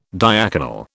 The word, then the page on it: diaconal: British English